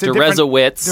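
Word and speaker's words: Deresiewicz: It's Derezowitz. – Derezowitz. – Rhymes with Jesuits.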